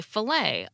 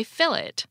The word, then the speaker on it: filet: Most of us in the US say filet a lot like French without pronouncing the T. However, in Australia, the word is fully assimilated into Australian English. They say fillet.